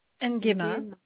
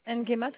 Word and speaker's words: Nguema: N-G-U-E-M-A. – Ngema? – Oui. – Alors, c'est tout attaché? – Ngema, c'est tout attaché. – Ngema, tout attaché? – Oui.